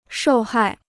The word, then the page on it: 受害: shòu hài